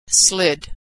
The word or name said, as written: slid